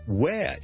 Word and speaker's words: where: 仲有啲咩例子咧？Where 亦都係 WHERE，有啲人將會讀成 where。咁啊，唔好当佢读错啦。